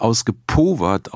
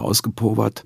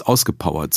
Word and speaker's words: ausgepowert: Ja, es muss natürlich ausgepowert ausgesprochen werden, weil es von der Poverté kommt, der Armut oder Pauvreté.